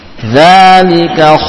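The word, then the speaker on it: Dzalika (ذلك): Dzalika khairun. – Dzalika khairun. – Dza. Ya. Ada i'timad atau yakni terasa, ya, pada bagian ujung lidah dengan ujung gigi ada tekanan suara padanya untuk pengucapan huruf dzal.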